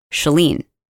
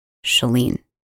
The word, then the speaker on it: Chalene: My name is pronounced Shah, as in shut the door. Shalene. Shalene. It's the Shalene Show, not the Shalene Show.